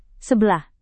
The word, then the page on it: sebelah: indonesian